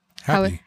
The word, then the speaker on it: HAPI: It's spelled H-A-P-I. – Happy. – Happy.